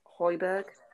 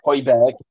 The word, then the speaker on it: Højbjerg: Højbjerg. – Højbjerg.